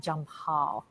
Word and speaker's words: Jampal: So yes, we are all over the world today, and we are talking about Jampal, and I hope I am pronouncing that correctly.